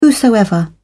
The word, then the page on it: whosoever: British English